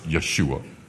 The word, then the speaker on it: Yeshua: Remember that Jesus' name was Joshua, or as they would pronounce it, Yeshua.